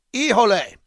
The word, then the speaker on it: Hijole: Ijole is smooth, easy to drink, straight on the rocks in your favorite cocktail. It's spelled H-I-J-O-L-E. Ijole.